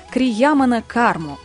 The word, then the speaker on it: Криямана карма: Мы говорим сегодня о законах кармы и начали разбирать Криямана карму. Правильно я произнесла? – Да, совершенно верно, да.